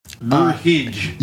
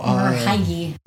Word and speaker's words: Merhige: But yeah, this is the weirdest spelling of marriage I've ever seen in my life. But apparently that's how it's pronounced. – Oh, is it? – Yeah. – Merhige. – Yeah, it's M-E-R-H-I-G-E.